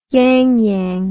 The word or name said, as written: yangyang